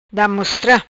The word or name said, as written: Damostra